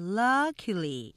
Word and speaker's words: luckily: 그래서 luck luckily 삼음절로 하면서 앞에 액센트 강조야 해 luckily 이렇게 하셔야 강량량 자, 그다음에 이 고소공포증이라는 이 단어는 이렇게 어려운 단어들은 음절 표시가 없으면 정말 어, 정확한 발음을 알기 어렵죠.